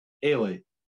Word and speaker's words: Eilidh: Ailey. – Ailey. Spelled E-I-L-I-D-H. – Ailey, yeah. Ailey Sinclair.